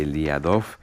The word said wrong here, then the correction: Liadov: Lo he dicho bien, ¿no? Era Liadov. – Sí, Liadov.